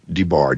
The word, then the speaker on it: DeBar: Okay, my Facebook page, my name is spelled Don, D as in David, O, N as in Nancy, new word, DeBar, D as in David, E, B as in Bob, A, R.